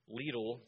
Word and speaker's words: Lidl: I was at Lidl the other day, which I learned that's how you pronounce that.